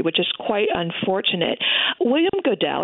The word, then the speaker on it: Goodell: But there's one character that starts off towards the beginning of the book, and it's just fascinating, and I'm not sure how to pronounce his name correctly. Is it William Goodell or Godel? – William Goodell.